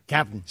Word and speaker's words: Cap'n: Cap'n. – C-A-P apostrophe N. Please.